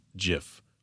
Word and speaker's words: GIF: He goes, it's Jiff, like Jiffy. And I've had people argue with me. It's GIF. No, it's Jiff. – No, it is pronounced Jiff. Like the guy that actually is accredited with the creation of them said it's pronounced Jiff.